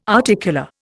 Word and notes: articular: Indian

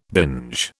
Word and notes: binge: British English

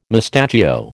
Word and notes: mustachio: American English

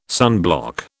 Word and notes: sunblock: British English

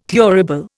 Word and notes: thurible: Indian

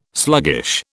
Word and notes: sluggish: British English

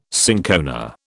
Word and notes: cinchona: British English

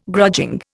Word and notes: grudging: Indian

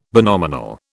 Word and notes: binominal: British English

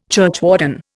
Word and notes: churchwarden: Indian

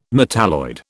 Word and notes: metalloid: British English

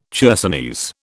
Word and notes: chersonese: British English

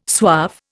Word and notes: suave: Indian

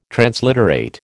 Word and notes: transliterate: American English

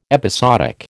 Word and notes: episodic: American English

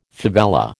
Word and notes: favela: American English

